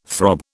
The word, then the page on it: throb: British English